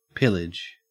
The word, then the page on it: pillage: Australian English